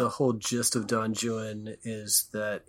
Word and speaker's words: Juan: And I know it's Don Juan. He pronounced it Juan. He knew Spanish. – Yeah. – He spoke Spanish. He read Spanish, but still his Anglicisms are there, and he rhymes it Juan. He rhymes it with things like new one.